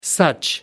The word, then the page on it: such: American English